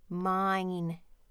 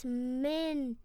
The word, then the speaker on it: Minecraft: I think the point of Minecraft is Mindcraft, actually. – Well, no, it's mine, M-I-N-E, because it's a silent E that turns the I into an I. Mine. Minecraft.